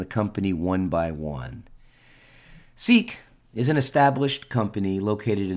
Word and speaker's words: CEEK: Seek, which by the way is spelled C-E-E-K, has an ICO that's coming up in a couple of weeks, and Gazecoin recently completed their ICO about a month ago.